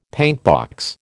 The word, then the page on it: paintbox: American English